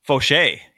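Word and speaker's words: Faucher: Calvin Fauché. Is that how you say it? Foucher? – Fauché. Yes, Fauché. – Oh, we can't have Crochet and Fauché on the same team. – Dude, are you kidding? That would be sick. – I thought it was Foucher this whole time.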